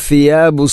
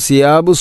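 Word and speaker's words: ഫിയാബു (ثياب): ആലിയഹും ഫിയാബു സുന്ദുസിൻ. ഇവിടെ ഫിയാബു എന്നതിലെ ഫാഇൻ്റെ ഉച്ചാരണം പ്രത്യേകിച്ചും ശ്രദ്ധിക്കേണ്ടതാണ്. ആലിയഹും സിയാബു സുന്ദുസിൻ എന്ന് പറയുമ്പോൾ അത് തെറ്റാവും, കാരണം ഫാഇൻ്റെ മഖ്റജ് അതിൻ്റെ ഉച്ചാരണം പൂർണ്ണമായിട്ടും മാറുന്നു. അപ്പോൾ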